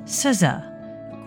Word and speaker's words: SZA: Her name is pronounced SZA, according to the experts at Babbel.